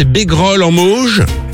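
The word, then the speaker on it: Bégrolle-en-Mauges: C'est bien ça, c'est Bégrolle en Mauges.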